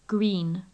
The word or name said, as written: green